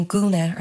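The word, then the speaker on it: gúna: And putting Guna or dress at the end, Guna means dress, it just creates this nice echo of the long U vowel sound.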